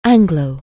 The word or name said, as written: Anglo-